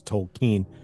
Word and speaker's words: Tolkien: And then, yes, I am one of those hipsters who says Tolkien. – That's the way you said, that's the correct way to pronounce it.